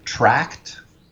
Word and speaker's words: Trakt: So John mentions in that episode of Rec Diffs an app called Tracked. Which is spelled TRAKT.